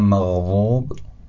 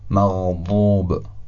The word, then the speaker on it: مغضوب: یا کلمه مغضوب، اول میگم مغضوب، حالا مغضوب. کدوم وا بود کدوم ضاد؟ بعد اولی وا یعنی تنزین بود، خیلی نرم تلفظ شد نسبت به واد، و دومی حرف واد.